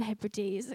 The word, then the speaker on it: Hebrides: Milne felt called to go and minister to a tribe in the New Hebrides, I want to say. Don't know how you pronounce it, probably that. Yeah, that's the one, Hebrides, okay.